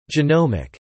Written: genomic

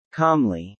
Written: calmly

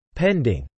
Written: pending

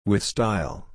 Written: with style

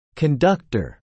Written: conductor

